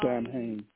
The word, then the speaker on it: Samhain: but I believe it's spelled S-A-M-H-A-I-N. I'm not for sure. As Emmanuel said, you know, you can do your own research because the Celtics called Halloween something else. So, yes, Samhain.